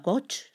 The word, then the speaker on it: coach: Y tenemos sesiones con una coach, ¿se dice así? – Sí, muy bien.